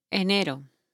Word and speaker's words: Enero: Enero. – Enero? – Yes. – I don't know any of the months. – E-N-E-R-O.